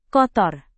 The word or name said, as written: kotor